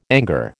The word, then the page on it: anger: American English